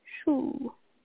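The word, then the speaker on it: chou: And it's spelled funny, so it's C-H-O-U-S. – Yeah. Let's see. Shoe.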